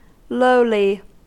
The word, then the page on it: lowly: American English